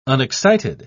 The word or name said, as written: unexcited